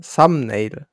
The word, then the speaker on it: Thumbnail: Aber wusstest du, das ähm, habe ich gestern auch gelernt, Thumbnail wird nicht hm, Thumbnail ausgesprochen, sondern Thumbnail. Also ohne das B, aber wird mit B geschrieben.